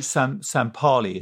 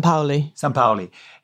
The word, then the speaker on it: Sampaoli: Who, Sam Parley? is that how you pronounce it? Who speaks Spanish? So I would say San Sampaoli.